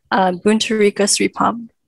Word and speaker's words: Boonie Sripom: I just want to correct pronunciation, so anyone else who wants to pronounce my name, Boonie Sripom.